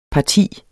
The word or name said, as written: parti